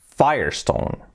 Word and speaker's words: Firestone: Así que la primera, que sería la marca de neumáticos Firestone, la pronunciación es Firestone. Completamente diferente.